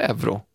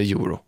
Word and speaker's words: euro: Så liksom ett offentligt svar där det står att Svenska Akademin har uppgett att det uttalas både euro eller euro. Man får välja vilket man vill.